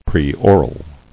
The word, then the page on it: Preoral: pre·o·ral